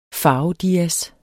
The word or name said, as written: farvedias